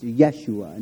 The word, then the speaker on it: Yeshua: And so Jesus' name in Hebrew is pronounced Yeshua.